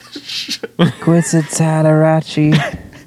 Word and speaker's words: Kwisatz Haderach: Kwisatz Haderach.